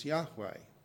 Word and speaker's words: Yahweh: Now, in the Old Testament, when you see the word Lord, L-O-R-D, all in capital letters, in the original, that's something like Y-H-W-H, which we think is most likely pronounced as Yahweh,